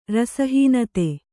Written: ರಸಹೀನತೆ

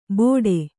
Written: ಬೋಡೆ

